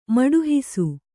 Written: ಮಡುಹಿಸು

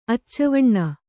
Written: ಅಚ್ಚವಣ್ಣ

